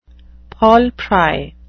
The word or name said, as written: paul pry